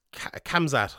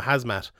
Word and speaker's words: Khamzat: we'll talk about Chimaev in a second, but everyone's giving out to me because I pronounce his name wrong. It's Khamzat. I've been calling him Hazmat, which is completely incorrect, but sure, look, these things happen.